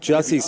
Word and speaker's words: Jussie: I thought it was Jussie, but then I was hearing Juicy, but. – Oh. – I'm thinking Jussie. – Jussie Smollett.